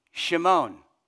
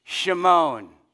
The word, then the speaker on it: Shimon: It's a common Hebrew name, and it would have been pronounced Shimon. Everyone say that with me. Shimon.